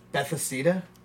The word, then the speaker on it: Bethesda: Bethesda.